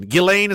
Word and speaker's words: Ghislaine: He's calling it Ghislaine. Ghislaine is how you say her name, but she's a terrible person, so I will allow this.